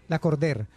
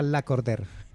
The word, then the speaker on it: La Cordaire: Es que es un nombre francés. Sí, se escribe La Cordaire, pero se pronuncia La Cordère.